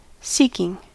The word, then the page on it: seeking: American English